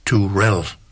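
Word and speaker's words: Terrell: Tammy var korrekt nok, men efternavnet, det udtales faktisk Turell. Altså Tammy Turell hedder hun, selvom det staves T-R-R-I-L-L.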